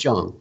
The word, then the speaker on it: Jong: I think Erica Jong or Jong? I'm not sure how to pronounce her. – I believe it's Jong, yeah.